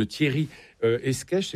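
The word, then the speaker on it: Thierry Escaich: Vous parlez du titulaire des orgues, un des organistes, les compositeurs les plus extraordinaires, il s'agit de Thierry Esquèche, c'est comme ça qu'on le prononce.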